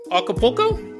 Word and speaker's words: Acapulco: Acapulco? Right, that's how you say it.